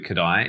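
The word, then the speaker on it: Kodály: When I was interested to introduced, I should say, to Kodai over here maybe 10 years ago. And I should say, for those listening, if you're not familiar with Kodai, it's spelled K-O-D-A-L-Y. Kodai, that's the guy's name.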